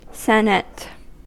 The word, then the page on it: senate: American English